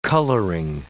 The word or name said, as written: coloring